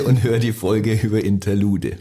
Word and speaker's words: Interlude: Spricht man das so aus? Interlude? – Ja.